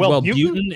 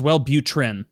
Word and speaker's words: Wellbutrin: You said Wellbutrin. It's Wellbutrin. Yeah.